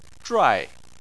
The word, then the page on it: zhuai: 4